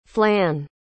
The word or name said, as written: flann